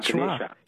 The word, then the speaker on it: Ganesha: So the A at the end is pronounced like a schwa, Ganesha.